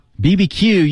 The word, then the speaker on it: BBQ: BBQ, you can, yeah, BBQ, it takes as long to say as barbecue, because it's the same number of syllables, so a lot of people say barbecue, but you could say BBQ, no problem.